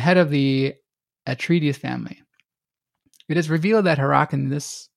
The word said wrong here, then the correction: Harkonnen: how do you say it? Harkonnens.